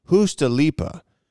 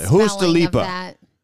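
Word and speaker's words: juustoleipä: And I found out that the way that you pronounce it over in Europe is, Hustalipa is how you pronounce it. Hustalipa.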